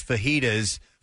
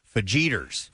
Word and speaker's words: fajitas: My husband pronounces fajitas fajiters.